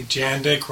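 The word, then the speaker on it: Jandek: Hey, Jandik rhymes with Jack Chick.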